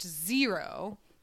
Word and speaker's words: XRO: The group is pronounced Zero, but it's spelled XRO.